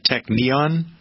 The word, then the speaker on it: Technion: I think it's, I'm going to spell it because I don't know how to pronounce it. T-E-C-H-N-I-O-N. Would you pronounce that Technion? Technion, I think.